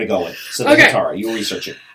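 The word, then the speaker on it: hetaera: And then I came across, I was researching, you know, the hetera. – Mm-hmm, I think it's pronounced hetara, but hetera.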